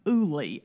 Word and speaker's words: Ooly: So I actually bought a few weeks ago, I think if I'm not wrong, we're going to pronounce this brand Ooly, O-O-L-Y.